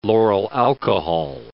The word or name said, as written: lauryl alcohol